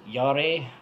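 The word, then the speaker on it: Jarre: is it Yare? Because I believe it's French.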